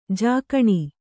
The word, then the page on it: ಜಕಣಿ: jakaṇi